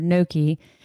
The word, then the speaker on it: gnocchi: So if you're not familiar with it yet, it's spelled G-N-O-C-C-H-I. CCHI. So if you're looking for it, that'll kind of help you know what to look for. Gnocchi, it's got the silent G, so it's G-N-O-C-C-H-I. But gnocchi, so it is this little kind of, it's usually potato-based.